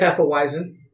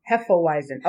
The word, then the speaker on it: Hefeweizen: Hefeweizen. – Hefeweizen. Okay, that's what kind of... – It's a Hefeweizen. We can discuss Hefeweizens later on another show. But this particular beer, as a Hefeweizen, some characteristics you'll get are pretty much like orange.